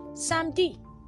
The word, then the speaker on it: samedi: In most cases, the letter E is being omitted when pronouncing. So that's why the French speakers hardly stress themselves to say samedi. Instead, they say samedi.